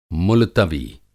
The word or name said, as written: मुलतवी